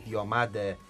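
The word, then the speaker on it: Diomande: Πέμπτος Γιάν Ντιομαντέ, και μου λέει ο φίλος μου Μιχάλης Ντιομαντέ είναι η σωστή προφορά του Ντιομαντέ κατά πολλούς.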